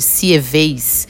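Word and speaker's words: Sieyès: 17 de junho de 1789, por sugestão do abade Sieves, que se, que se escreve S-I-E-Y-E, acento de crase e S, o terceiro estado proclama a Assembleia Nacional.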